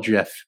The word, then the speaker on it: GIF: Or uh, we we call it GIF. It's like giraffe, um, but it's a soft G.